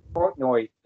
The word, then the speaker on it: Portnoy: Portnoy is like how you say his name.